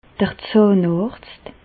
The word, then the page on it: d'r Zàhnàrzt: Bas Rhin; Reichshoffen